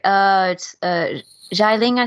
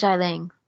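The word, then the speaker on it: Xialing: Zhai Ling, I think it's pronounced. – Sure. – I think it's the sister? Yeah, I think so, it's Zhai Ling.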